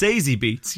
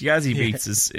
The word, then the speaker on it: Zazie Beetz: It's Zazie Beetz. Yazzie Beetz is just... It's probably... I feel like it's racist. – A little bit. – Was I racist? – You've mispronounced Yazzie Beetz, basically. You've just done a Ron Burgundy on the word Yazzie Beetz.